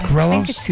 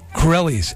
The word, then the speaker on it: Corelli: Subject: Sean's movie in response to Captain Corello's mandolin, is that how it's pronounced, Beth? Corello's? – yeah. Corelli? – Corelli's. Mm-hmm. Oh, Captain Corelli's mandolin.